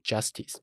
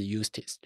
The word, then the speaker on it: Eustace: 那尤斯特斯他有讲他的英文叫 Eustace，但其实他的拼法其实因为它是 JU 开头，所以它其实念起来有点像 Justice，如果你用英文去念的话像 Justice，但其实它强调说哦，没有，它的发音是 Eustace，就是尤斯特斯就对、哦、他刚上任，他从地球这边有点半被放逐过来这边，然后他其实因为不属于在这个原罪城炼狱当中任何一派、哦、所以他就被调过来了。